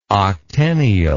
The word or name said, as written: octennial